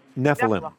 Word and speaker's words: Nephilim: Nephilim. Yeah. – Yes. Can you expand on what that is and how I missed it all these years? – Well, yeah, what you actually have here is the Nephilim recast as the Watchers in the movie,